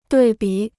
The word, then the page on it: 对比: duì bǐ